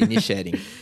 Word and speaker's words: Inisherin: E a gente está falando aqui de Os Banshees de Inisherin. Não sei se eu falei certo. Inisharin. É, Inisherin.